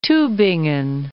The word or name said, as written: Tubingen